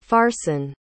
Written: farson

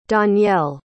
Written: donyelle

